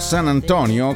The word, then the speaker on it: San Antonio: San Antonio, o San Antonio come si dovrebbe pronunciare, ma loro sono pigri e tolgono le finali, è una delle città più grandi degli Stati Uniti, la settima città per l'esattezza.